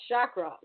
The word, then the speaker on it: chakra: We're going to talk about chakra, right? Am I saying it right? – You're saying it right.